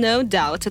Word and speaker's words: No Doubt: И вот в конце э, самая частая ошибка, по крайней мере, раньше она была, когда еще интернета не было, э, это, э, это группа No Doubt mm. – который все произносили именно так, по крайней мере, в моих гамелях, тут ничего не исправишь. No doubt. Э, ну, а, а сейчас уже все знают, что это no doubt, и никаких там больше b не существует.